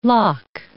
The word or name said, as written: lock